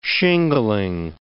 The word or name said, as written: shingling